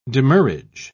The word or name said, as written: demurrage